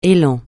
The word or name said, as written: élan